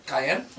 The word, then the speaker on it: Cayenne: Cayenne? – Yeah. – It's not Cheyenne.